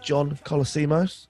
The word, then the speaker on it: John Colosimo: I'm here with John Colosimo.